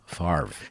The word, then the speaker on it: Favre: Favre. – Favre. Ha. – Communist. What's wrong with you? – Wow, I'm not going to live that down, am I? But Favre. – He's a legend. You got to get his name right.